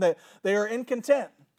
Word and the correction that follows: incontinent: or incontinent.